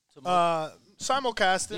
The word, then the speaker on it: simulcasting: How do you say it? Like multiple? – Simulcasting. – Yeah, simulcasting.